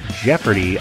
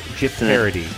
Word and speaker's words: Jeopardy: And not joining us across the country today is Kelly, because Kelly is off doing her Jeopardy auditions today. – I think you mispronounced that. – Oh, Jeopardy. I'm sorry.